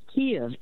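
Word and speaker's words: Kyiv: So if you know Stevie Wonder, the song's in the key of life, say it to yourself, song's in the key of life, then take off songs in the key of Kiev. Kiev is how you should pronounce the capital of Ukraine. It's not Kiev, it's not Kiev like the Russians.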